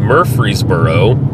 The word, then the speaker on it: Murfreesboro: We're driving between Nashville and Murfreesboro to the locals. Silent R in there.